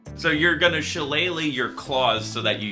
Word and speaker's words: Shillelagh: Because Shillelagh.